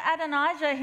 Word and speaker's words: Adonijah: David, King David, shirked his duty to, I'm going to say it wrong, Adonijah, thank you. Shirked his duty to Adonijah, his son.